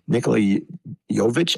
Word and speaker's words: Nikola Jovic: Nikola Jovic, I think is how you say it. – Yep.